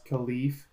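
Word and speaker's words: Kalif: And not one person can say, hey, Mike, you know, on a commercial break, hey, Mike, it's actually pronounced Khalif.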